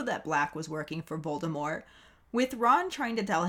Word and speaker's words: Voldemort: But maybe Ron just doesn't like when people say Voldemort because he finds the silent T at the end to be pretentious as fuck.